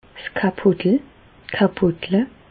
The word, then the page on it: 's Kapotel: Bas-Rhin; Reichshoffen